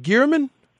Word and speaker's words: Girmann: Is it Girmann? – Yeah, you got it.